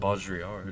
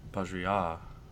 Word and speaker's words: Baudrillard: I know the emphasis on the is on the last syllable because it's French, but I don't know how to do that. – Baudrillard.